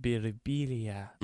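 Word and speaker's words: Birbiglia: When I looked at it at first, I thought it might be the silent G. – Yeah, no, Birbilia. I mean, I get that a lot. Sometimes people come up to me, they'll be like, in Italy, it's pronounced Birbilia. Mm.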